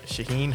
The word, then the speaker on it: Shaheen: Seton Hall, they hired, how do you say, Shaheen? – Shaheen Holloway.